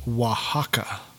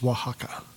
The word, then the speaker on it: Oaxaca: It's pronounced Oaxaca. – That place. – Yeah. – I thought he said a word. – Oaxaca or whatever. Oaxaca, yeah. – That's a place? – It's Oaxaca.